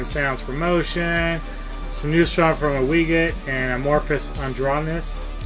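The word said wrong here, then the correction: Androgynous: Adronus, Adronus, yes. I can pronounce that. – Adrogenous. – Adrogenous. – Thank you.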